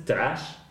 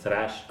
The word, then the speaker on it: thrash: Entonces, este, es que, bueno, si nos vamos incluso a, definición de, thrash, no hay, o sea, una cosa es trash, que eso es basura, y el género musical se pronuncia como thrash, como si fuera una especie de R arrastradilla, medio no fuerte, porque incluso se escribe T-H-R-A-S-H.